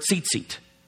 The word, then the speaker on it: tzitzit: And in Hebrew, we use the word tzitzit. This was called a tzitzit. Say that with me. Tzitzit. That's fun to say. You'd spell it today like T-Z-I-T-Z-I-T, or you'd spell it like that in Hebrew.